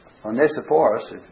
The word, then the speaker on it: Onesiphorus: And he says, the Lord give mercy unto the house of Onesiphorus, Onesiphorus, as some say. And that's probably a better way to pronounce it.